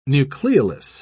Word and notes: nucleolus: British English

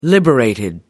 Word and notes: liberated: American English

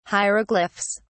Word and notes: hieroglyphs: British English

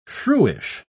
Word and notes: shrewish: British English

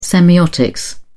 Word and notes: semiotics: British English

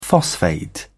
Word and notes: phosphate: British English

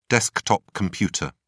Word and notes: desktop computer: British English

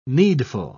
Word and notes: needful: British English

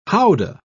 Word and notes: howdah: British English

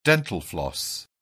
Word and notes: dental floss: British English

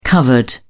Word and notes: covered: British English

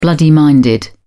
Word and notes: bloody-minded: British English

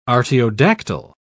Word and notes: artiodactyl: British English

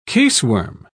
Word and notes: caseworm: British English

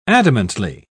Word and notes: adamantly: British English

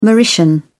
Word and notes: mauritian: British English

